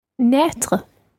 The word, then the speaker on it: naître: Now, the next verb, I suppose there's a certain element of motion involved here because it's the verb to be born, naître. – Naître. – It's spelled N-A-I circumflex, T-R-E, naître.